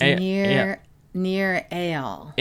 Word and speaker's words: Nir Eyal: Did I pronounce his name correctly? – No, you did not. – Please correct me. – It's Nir Eyal. – Eyal. – Although it's kind of spelled Eyal, but he pronounces Eyal.